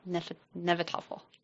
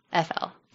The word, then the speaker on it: Hnefatafl: So now we have King Horik and Ragnar playing a game called Hnefatafl. – Falafel? – Hnefatafl. – Sounds made up. – It's actually spelt Hnefatafl.